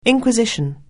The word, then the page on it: inquisition: anglais